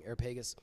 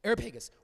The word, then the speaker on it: Areopagus: are different ways to pronounce it. I, I, I grew up hearing Areopagus. This is like, um, uh, I think it explains. Areopagus, where they said to him, May we know what this new teaching is that you are presenting?